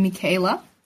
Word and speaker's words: Michaela: I am Michaela. This is the American way to pronounce my name.